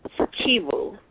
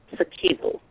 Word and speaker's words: Sikivu: Sikivu. The emphasis is on the second syllable. Sikivu. – Sikivu.